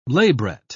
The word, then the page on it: labret: British English